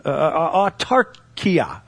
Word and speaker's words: autarkia: Now that word for contentment in the Greek is artakio. autarkia. Let's say it right. Autarkia.